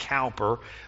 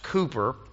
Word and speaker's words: Cowper: And I know it looks like Cowper, but it's pronounced Cowper.